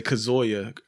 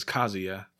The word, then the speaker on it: Kazuya: We used to always say Kazuya or Kazuya back in the days. We didn't know how to pronounce it. It's Kazuya.